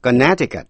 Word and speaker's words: Connecticut: Sau đây, âm C câm. Connecticut. Connecticut là tên tiểu bang, chữ âm C câm. Quý vị nghe lại, to connect là nối lại, liên hệ, to connect, âm C có phát âm, connect, nhưng Connecticut, quý vị nghe lại, connect, Connecticut. Và một từ ngữ nữa, Tucson, T-U-C-S-O-N, Tucson, T-U-C-S-O-N.